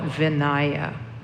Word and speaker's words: Vinaya: And they were written down in three collections: the sutras, which were the words of the Buddhas, and then the Vinaya, which is spelled V-I-N-A-Y-A, or some people call it Vinaya. Vinaya or Vinaya, which is the monastic discipline rule book.